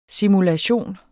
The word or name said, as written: simulation